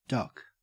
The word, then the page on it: duck: Australian English